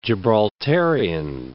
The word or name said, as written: Gibraltarian